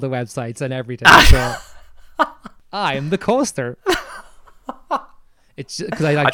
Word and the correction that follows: co-star: think you mean you're the co-star, not the coaster.